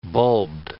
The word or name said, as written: bulbed